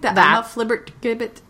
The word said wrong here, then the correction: flibbertigibbet: Flibbertigibbet. – Flibbertigibbet! – Flibbertigibbet! Flibbertigibbet. – If you read the pronunciation guideline.